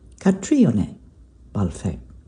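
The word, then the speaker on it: Caitríona Balfe: From 875, a classic English country estate on the outskirts of the Cotswolds, the setting of this month's cover shoot with actor Kytriona Balfe. Kytriona Balfe. Accent on the I there, I think.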